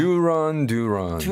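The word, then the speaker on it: Duran Duran: 여자아이들의 인기를 구가했던 듀란 듀란 제대로 원어 발음해 주세요. – 듀런 듀런 듀런 듀런이군요.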